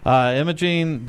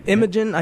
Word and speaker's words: Imogen: Imogen, did I say that right? – Imogen, I think we'll have to ask her.